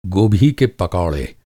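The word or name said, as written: पकौड़ा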